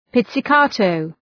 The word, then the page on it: pizzicato: ,pıtsı’kɑ:təʋ